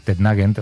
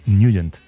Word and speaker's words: Nugent: Hombre, siempre lo hemos llamado en el barrio Tednagent o Tednagent. – Claro, ¿verdad? Yo era de Tednagent. – Pero la pronunciación correcta es Nugent. – Te fuiste a Washington y descubriste que era Nugent.